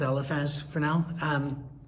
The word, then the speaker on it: Eliphaz: We're going to look at Eliphaz's, and by the way, his name is pronounced several different ways, but we're going to stick with Eliphaz for now.